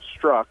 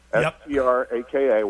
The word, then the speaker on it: Straka: Brandon Straka, he pronounces it Struck, Straka, Why I Left the Democrat Party.